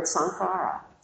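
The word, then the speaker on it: saṅkhāra: And this is where I was looking up some in around the suttas and conditioning, and that's where I found the word sankhara. Sankhara is the way you pronounce it, I believe, with the accent over the second A, which is really difficult to translate.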